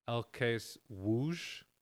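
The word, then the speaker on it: ŁKS Łódź: It's pronounced LKSWOOSH.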